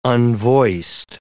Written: unvoiced

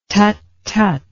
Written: tut tut

